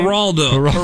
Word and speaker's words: Geraldo: Geraldo? Is that how you say his name? – Geraldo.